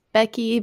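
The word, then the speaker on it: Beky: My name is Becky.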